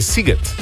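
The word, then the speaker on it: Sziget: Tukaj nas je nekdo od vas poučil, da se SZ v madžarščini izgovarja kot S, torej Siget.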